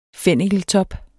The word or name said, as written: fennikeltop